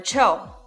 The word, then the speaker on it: Choo: Jimmy Cho. Jimmy Cho. 很多同学都好奇，他叫 Jimmy 周，为什么会变成 Jimmy Cho 呢？因为 Jimmy 是香港人，而 Cho 是香港州的发音，就是我们所说的广东话，所以同理可推，这个品牌叫 Jimmy Cho。